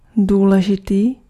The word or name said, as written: momentous